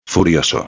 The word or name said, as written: furioso